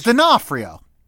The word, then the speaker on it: D'Onofrio: It's D'Onofrio. – D'Onofrio. – Yeah. I thought you were making a joke. – The apostrophe is an N. No, I'm sincerely. – No, it's D'Onofrio. – Clueless. D'Onofrio.